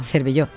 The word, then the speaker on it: Cervelló: y ahí tenemos el Palacio de Cerveró, de Cervelló. – Cervelló. – Es que no lo digo bien nunca.